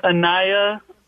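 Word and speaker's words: Anaiah: Her name's Anaya Vian Marie is her name.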